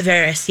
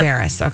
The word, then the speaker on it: Varys: How do you say his name? Is it Varys? – Varys, yeah. – Varys. Okay, good.